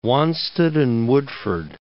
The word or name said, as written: Wanstead and Woodford